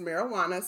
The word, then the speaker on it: Marijuana: Not Mary Jane. Not Mary, then Juana, then Pepsi. Her first name is Marijuana, spelled like marijuana.